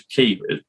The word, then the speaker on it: quay: It was Key, Q-U-A-Y. – Wow. – And so a key is that thing of ships, you know, a key, you know, where you dock a boat, but it's spelt with a Q.